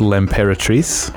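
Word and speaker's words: L'Impératrice: I think it's pronounced L'Imperatrice, which means the Empress.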